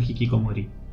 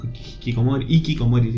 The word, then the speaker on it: hikikomori: Decían que era hikikomori, dicen los españoles sin pronunciar la H.